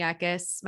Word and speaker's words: Eckis: But you guys can follow my personal journey just at Whitney Eckes. My last name is spelled E-C-K-I-S.